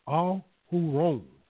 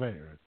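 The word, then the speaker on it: Aharon: But the fullness of his name, Ahuron, that is the Hebrew pronunciation of Aaron, Ahuron.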